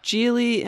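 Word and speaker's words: Gigli: And anyway, shortly after they married, J-Lo met Ben Affleck with Geely.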